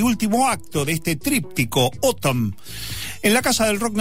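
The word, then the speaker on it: ATUM: Resulta que el más reciente trabajo de estudio de Smashing Pumpkins es una obra conceptual llamada Autumn, que se pronuncia como decir otoño en inglés, pero se escribe A-T-U-M y está dividida en tres partes o, mejor dicho, tres actos.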